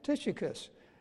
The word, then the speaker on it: Tychicus: I spent many hours working on this message, and most of those hours were learning how to pronounce this guy's name, Tychicus. Not being Greek myself, I had some difficulty with that, but as far as I can tell from the authorities, that this is how you say his name.